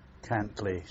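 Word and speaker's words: Cantlay: William Gordon Cantley, spelt with an A, C-A-N-T-L-A-Y, 13th of December 1921.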